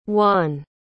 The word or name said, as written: whan